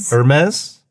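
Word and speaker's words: Hermès: I'm going to say Hermes? Hermes. Is that how you say it? – Yeah.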